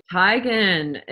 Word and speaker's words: Teigen: It's actually Teigen. It is pronounced according to this website. She admitted that it's a Norwegian last name, is actually pronounced Teigen, not Teigen. And yeah, she has introduced herself as Teigen, according to this article, but her family's told her the proper way to say her name, which is actually Teigen.